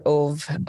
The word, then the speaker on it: Ove: So the story is A Man Called Ove, or Ove, O-V-E is how it's spelled.